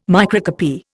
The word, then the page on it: microcopy: Indian